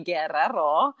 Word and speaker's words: Guerrero: Where you properly say my name, Amy Guerrero. And... Oh. But I don't expect anybody to roll their R's like I do.